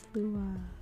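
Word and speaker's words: Sluagh: Slua. It's S-L-U-A-G-H. Oh, it's just Slua. Slua. Okay. Whatever. I guess I should have known that because most of the G's they don't pronounce anyway.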